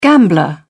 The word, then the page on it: gambler: British English